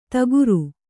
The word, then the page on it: ತಗುರು: taguru